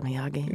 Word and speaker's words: Miyagi: Mr. Miyagi.